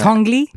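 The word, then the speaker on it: Tinguely: First of all, we're going to go to Basel in Switzerland and Museum Tangeli. I hope I'm pronouncing yes. – that right. – Tangeli.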